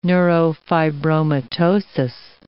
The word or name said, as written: neurofibromatosis